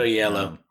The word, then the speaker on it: Oyelowo: David Oyelowo.